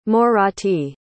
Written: morathi

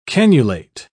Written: cannulate

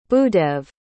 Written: bhudev

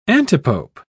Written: antipope

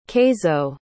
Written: keizo

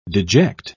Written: deject

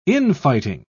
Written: infighting

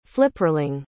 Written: flipperling

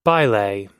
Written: baile

